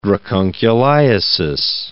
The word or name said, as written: dracunculiasis